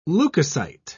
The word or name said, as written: leukocyte